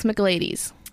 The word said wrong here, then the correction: Michaelides: McGlades is not it. McGlades. – Michaelides. – That's the one. Michaelides.